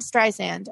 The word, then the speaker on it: Streisand: This is our Hollywood agent, Clint Streisand. Am I saying that right? Streisand, yeah. Like Barbara? – Yep. Yeah. You're saying that completely right.